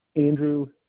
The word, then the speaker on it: Andrew: Yeah, Andrew Hildebrand. – Andrew, is it A-N-D-R-E-W? – That's right.